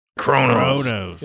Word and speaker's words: Qo'noS: Most of the Klingons pronounce it Kronos. – But it's spelled Q O N O S. There's no R.